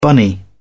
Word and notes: bunny: British English

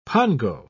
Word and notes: pongo: British English

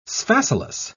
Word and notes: sphacelus: British English

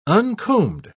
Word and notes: uncombed: British English

